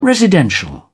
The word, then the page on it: residential: British English